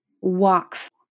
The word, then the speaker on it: waqf: Waqf. I believe that's how it's pronounced.